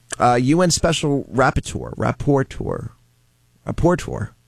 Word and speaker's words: Rapporteur: R-A-P-P-O-R-T. – Hold on, hold on. For National Dyslexic Week, go ahead. – R-A-P-P-O-R-T-E-U-R. – Rapporteur. You're close. – All right, good. – Yeah. No, say it again. – Rapporteur.